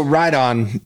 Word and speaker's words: Righton: So Ride On, everybody mispronounces it, but once they get it, they realize how many times you say Ride On every day. So it's a take on kind of that, you know, R-I-G-H-T-O-N.